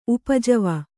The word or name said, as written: ಉಪಜೀವಿ